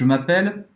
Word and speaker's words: m'appelle: Et « m'appelle », m'appelle. é aberto isso. M'appelle e, e acaba fini. Eu já tava falando em francês. E la prononciation fini ou elle », A pronúncia vai acabar no L.